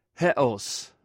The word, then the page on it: hews: greek